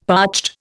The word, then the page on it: parched: Indian